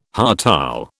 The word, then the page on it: hartal: British English